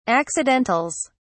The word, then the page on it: accidentals: British English